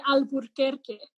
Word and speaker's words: Alburquerque: And there's an extra R in Albuquerque, Alburquerque. And so white people, though, just didn't know how to fucking say Alburquerque, like most things that require you to roll your R's. And so they changed the name officially to Albuquerque so that you wouldn't have that extra R.